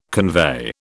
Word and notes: convey: British English

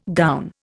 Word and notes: gown: Indian

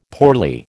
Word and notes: poorly: American English

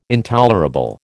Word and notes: intolerable: American English

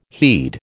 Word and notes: heed: American English

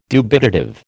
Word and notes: dubitative: American English

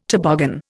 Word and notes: toboggan: Indian

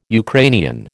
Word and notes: Ukrainian: American English

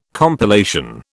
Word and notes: compilation: British English

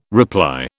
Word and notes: reply: British English

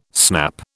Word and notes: snap: British English

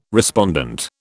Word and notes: respondent: British English

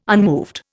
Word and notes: unmoved: Indian

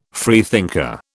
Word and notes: freethinker: British English

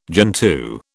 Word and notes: gentoo: British English

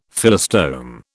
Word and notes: Phyllostome: British English

